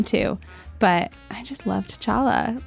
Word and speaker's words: T'Challa: Beyond, you know, your main couple, T'Challa and I said that wrong. T'Challa and Nakia.